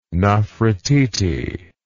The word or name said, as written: nofretete